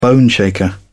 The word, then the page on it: boneshaker: British English